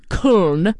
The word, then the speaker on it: Köln: And one of my favorite live albums of all time is the Köln, because that's how they say it in Deutschland.